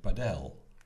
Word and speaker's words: padel: Dus P-A-D-E-L. En dat is niet, eh, niet helemaal gek, omdat in Spaans de klemtoon, als het eh, woord niet op een S of een N of een klink eindigt, dan is het altijd op de laatste lettergreep. Dus dan zeggen wij dus inderdaad eh, padel. Hm. – En toch klopt het volgens de padelspelers zelf niet. Hè. Zou die nadruk moeten liggen op de eerste A.